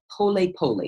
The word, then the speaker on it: pole pole: Now, when you're on the mountain, the guides, people who are going up and down, they have this Kiswahili words that say pole pole. It's spelled P-O-L-E, P-O-L-E, but it's pronounced pole plo pole.